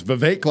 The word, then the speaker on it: Vivek: Speaking of Indians, it's Vivek like cake.